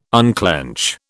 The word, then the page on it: unclench: British English